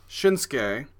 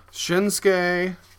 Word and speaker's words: Shinsuke: Shinsuke. It's like Asuka. – Shinsuke.